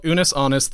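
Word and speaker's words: Unus Annus: It's called Unus Honest. That's spelled UNUSANNUS. That's honest, not anus.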